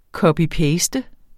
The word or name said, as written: copy-paste